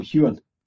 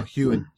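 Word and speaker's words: Heughan: It was on all the big talk shows, you know, both the main stars, you know, Katrina Balfe and Sam Hoogan, is it? – You wouldn't pronounce it like that, Gags. – How would you pronounce it? – It would just be Sam Hewen. – Oh, Hewen, right.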